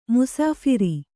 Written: ಮುಸಾಫರ

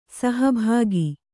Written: ಸಹಭಾಗಿ

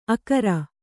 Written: ಅಕರ

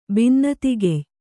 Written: ಬಿನ್ನತಿಗೆಯ್

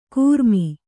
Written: ಕೂರ್ಮಿ

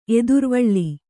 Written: ಎದುರುವಳಿ

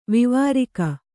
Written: ವಿವಾರಿಕ

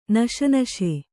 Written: ನಶನಶೆ

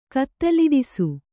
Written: ಕತ್ತಲಿಡಿಸು

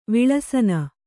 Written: ವಿಲಸನ

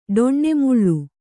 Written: ಡೊಣ್ಣೆಮುಳ್ಳು

